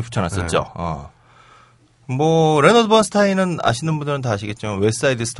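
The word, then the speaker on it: Bernstein: 어그 근데 둘이 영어 스펠링은 똑같은데 발음은 좀 다르더라고요. – 음. – 엘머는 번스틴이라고 읽어달라고 하고 그다음에 이제 레너드 번스타인은 번스타인이 음. 읽어달라고 하는데 둘 다그 어, 유태계 성인인데 유태계인데 어, 엘머의 경우는 이제 헝가리에서 아버지가 네.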